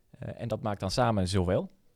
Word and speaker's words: Zowel: Ehm, van architectenbureau Zowel. Zeg ik het zo goed met deze klemtoon? – Dat klopt.